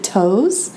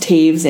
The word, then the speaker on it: Toews: She had explained that it's pronounced Taves in like a New York Times article.